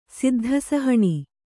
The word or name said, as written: ಸಿದ್ಧಸಹಣಿ